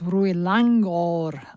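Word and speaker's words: Rued Langgaard: a neglected Danish master whose name is actually pronounced Rui Langgaard. I should have checked that in advance.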